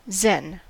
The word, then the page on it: zen: American English